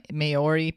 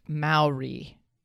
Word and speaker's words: Maori: And you remember I was saying the Maori people? – Mm-hmm. – It's not pronounced like that. It's pronounced Maori.